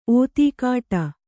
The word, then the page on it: ಒತ್ತುಗೊಡು: Kannada